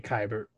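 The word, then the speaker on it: Keibert: How do you say that? – I would say Kybert.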